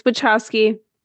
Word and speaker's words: Wachowski: Yes, it's Wachowski. That's correct. The T and the little curly thing is a CH sound.